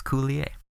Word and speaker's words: Coulier: He's Canadian. It's Coulier. – Coulier, I'm sorry. Is that French or something? – It's French.